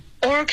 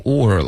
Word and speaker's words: all: All kids are embarrassed by their parents. – 各种发音都有，现在变成 or 了。 OK，它不是一个 or 是 or 的音，这个是 all，结尾呢舌尖要顶着上颚 all。